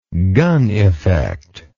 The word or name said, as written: Gunn effect